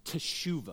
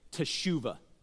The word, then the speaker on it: teshuvah: So there's an ancient Hebrew word, and it's pronounced teshuvah. Let me hear you say teshuvah.